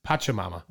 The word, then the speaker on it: Pachamama: Panchamama? Ooh, I like Panchamama. – If that's how you pronounce it. – It's Pachamama.